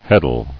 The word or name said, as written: Heddles